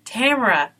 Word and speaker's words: Tamara: So I had to use, rhymes with camera. Tamara.